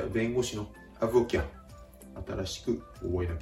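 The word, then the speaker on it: avocat: 発音のポイントこれはアブオキャの V の発音です V の発音は英語と同様に唇下唇を軽く噛んで息を漏らす感じアブアブと発音するところがポイントですですがフランス語のこの息を漏らす発音というのは、英語の例えば V の発音よりもやや大げさに発音するところがポイント。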